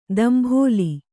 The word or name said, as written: ದಂಭೋಲಿ